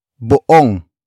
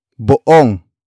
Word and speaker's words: buong: Sila is spelled as S-I-L-A. It means they in focus form. Up next we have buong. Buong. Buong. Buong is spelled as B U O N G.